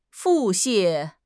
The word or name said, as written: 腹泻